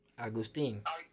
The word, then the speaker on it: Agustin: Agutin. – With a name like Khadija, I should be able to pronounce anybody's name. – Agutin. – Agutin. – Ting. Sting. Sting. Agustin.